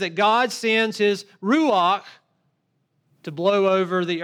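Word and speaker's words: ruach (רוּחַ): The Hebrew word is ruach. We would spell it in English, R-U-A-H, but it is pronounced ruach.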